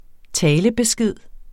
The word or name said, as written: talebesked